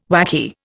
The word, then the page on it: whacky: Indian